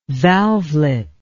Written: valvelet

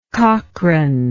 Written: cochran

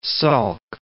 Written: Salk

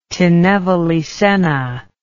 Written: tinnevelly senna

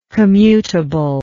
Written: commutableness

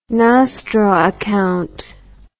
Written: nostro account

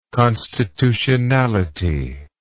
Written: constitutionality

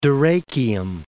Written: Dyrrachium

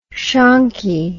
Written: shonkie

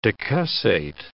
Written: decussate

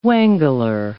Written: wangler

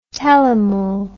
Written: telemole

